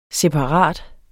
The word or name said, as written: separat